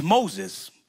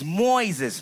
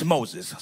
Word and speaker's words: Moises: It's Moses, but it has an I. And the I is silent. And so when people meet me, it's Moises. No. It's Moses.